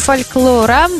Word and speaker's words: фольклора: Всемирный день фольклора. Фольклора, да, именно так я его произношу.